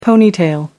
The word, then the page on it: ponytail: American English